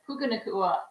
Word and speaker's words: Puka Nacua: Puka Nacua, Stafford.